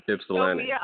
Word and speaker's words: Ypsilanti: Ypsilanti.